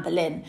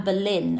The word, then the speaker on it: Boleyn: And Boulogne and Boleyn are pronounced fairly interchangeably in the period.